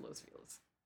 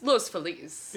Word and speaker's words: Los Feliz: In California, we pronounce it Los Feliz, but it's Los Feliz.